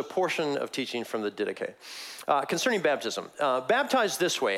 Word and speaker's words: Didache: It's spelled like Didache, which I think is a cooler way to say it, but the Didache.